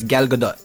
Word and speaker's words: Gal Gadot: And I believe the correct Israeli pronunciation. And if you were an Israeli, you could totally correct this. – Okay. – But it's Gal Gadot, right? – Gal Gadot. – Gal Gadot.